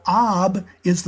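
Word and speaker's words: Abi (אבי): Well, Ab is the word for father. Abi is my father. The I ending, which is pronounced like an E, is possessive.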